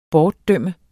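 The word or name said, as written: BORTDØMT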